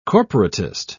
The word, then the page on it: corporatist: British English